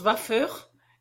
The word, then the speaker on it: faveur: Il se prononce vafeur et acquiert une très suave harmonie en passant par une bouche sachant bien parler le français.